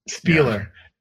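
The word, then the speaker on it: Spieluhr: It's like Spieler, Spiller, Spieler. – I think it's Spieler. – Spieler, yeah.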